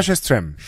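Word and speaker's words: Sjöström: 드레스라고 세스트램. 네. 아, 발음이 어떻게 됐죠? – 사라 셰스트램. 셰스트램. 네. 네 뭐 이 남녀 스웨덴 발음은 어렵죠.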